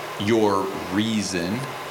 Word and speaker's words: Urizen: I mean, is it Urizen, your reason, your Urizen, like horizon?